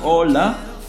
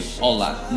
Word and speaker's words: hola: Hola, hola. And you didn't pronounce H in Spanish, so the words is actually hola, not hola, alright?